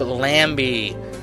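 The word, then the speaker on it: Lambi: And October 9th, they're going to be at Montreal at Club Lambie, which rhymes with Bambi, except it's with an L.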